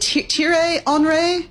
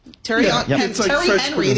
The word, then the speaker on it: Thierry Henry: And they also hired, is it Thierry Henry? – Yeah. – Is this how you say it? Terry Henry, the American way, former star player as its director of football.